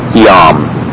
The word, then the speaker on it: jam: However, I believe virtually all liturgical texts use the J, and it is pronounced like a quick I, really a Y sound, as in yes. For instance, the word J-A-M, meaning already, is Yam.